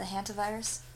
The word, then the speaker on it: hantavirus: The hantavirus? – How do you say it? – Hanta? – Hantavirus. – Yeah, hantavirus.